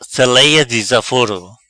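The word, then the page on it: Selea dizaforo: Patua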